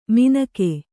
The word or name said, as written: ಮಿನಕೆ